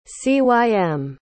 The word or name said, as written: cym